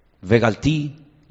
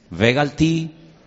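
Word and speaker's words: vegaalti: Sacaré, vejotsetí, libraré, vejizaltí, redimiré, vegaltí, yo tomaré velacachti. Las cuatro terminan en ti, ti, ti, ti. ¿Lo veis? ¿Podéis pronunciarlas conmigo? Bejotzeti, vejitzalti, vegalti, Velacachti.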